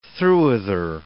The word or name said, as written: throughither